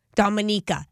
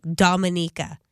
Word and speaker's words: Dominica: I was in Barbados, St. Lucia, Dominica. I said it wrong. Dominica.